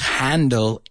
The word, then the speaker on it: Handel: Now, if his name is Handel E.L., no? E.L. Pero Handel L.E., sí, si le damos la vuelta a su nombre, se pronuncia exactamente igual, por eso te lo digo.